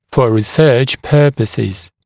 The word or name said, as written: for research purposes